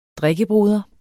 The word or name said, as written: drikkebror